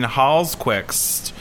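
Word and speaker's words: Hallquist: Like, literally, as we were walking into Matt's studio, we had talked about CNN reporting that Christine Hallsquist,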